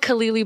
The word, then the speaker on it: Khalili: it's spelled Khalili, but it's actually Khalili.